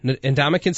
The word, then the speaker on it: Ndamukong: Ndamukong Suh? – I can't pronounce that.